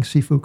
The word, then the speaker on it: Cifu: So as I said, CEO of Virtue Financial is with us today, Doug Cifu. Doug, I'm going to parenthetically pause. Am I pronouncing Cifu correctly or am I bungling? – You are doing a great job.